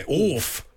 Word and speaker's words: Orff: O-R-F. – Like, okay. – Now, there's no other way of pronouncing it, is there? – Orff. No, that's it, yeah.